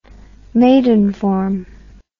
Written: Maidenform

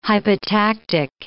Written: hypotactic

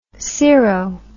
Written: sero

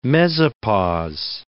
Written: mesopause

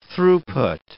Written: throughput

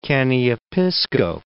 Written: Caniapiskau

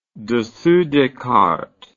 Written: dessous des cartes

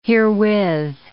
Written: herewith

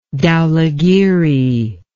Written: dhaulagiri

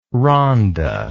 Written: Rhonda

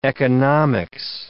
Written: economics

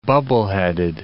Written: bubbleheaded

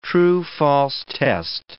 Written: true-false test

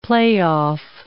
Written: play-off

